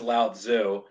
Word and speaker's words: Lao Tzu: So, David mispronounced his name, but it is Lao Tzu.